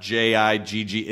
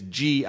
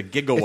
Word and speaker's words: gigawatt: So when a lot of people are surprised to find out that it's not JIGG, it's GI. It's a gigawatt.